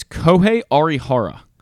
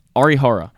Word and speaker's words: Arihara: It's Kohei Arihara. I believe that's how you say it. Arihara. Sounds about right.